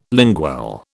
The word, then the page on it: lingual: British English